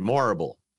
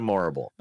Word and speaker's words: Morrible: Michelle Yeoh has reportedly boarded the Wicked adaptation in a key role too as Madame It's Madame Horrible, but with an M instead. Morrible. Madame Morrible.